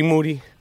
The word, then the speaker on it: Moody: Moody. Last name rhymes with duty.